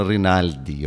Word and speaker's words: Rinaldi: Questão de sensibilidade, hoje trazendo música argentina, o tango, com uma das mais expressivas cantoras argentinas, que é a Susana Rinaldi. Eu falo Rinaldi porque é essa a pronúncia em castelhano. Para nós seria Susana Rinaldi, porque é Rinaldi com L.